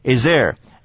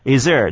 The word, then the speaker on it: is there: 因此呢啊，在念的时候，通常有个折中的方案，就是呢把那个 th 消掉，只念 is 的声音，通常是这样念的：is there, is there？那个 th 呢不发音的，来。